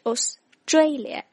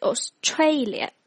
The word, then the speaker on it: Australia: 还有像 Australia, 应该是 Australia, 而不是 Australia。